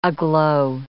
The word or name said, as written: aglow